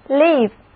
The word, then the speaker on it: live: Live, 别看它是一个开音节，但是字母 I 的读音是短的 I, 读作 live。